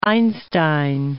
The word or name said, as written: Einstein